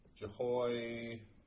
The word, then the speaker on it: Jehoiachin: His son, Jehoiachin, and it's pronounced Kin, Jehoiakim, has 3 months.